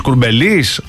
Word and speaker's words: Κουρμπέλης: Αυτοί θα προσπαθούν να μάθουν ακόμα πώς τονίζεται, πού τονίζεται το Κουρμπέλης. Κουρμπέλης.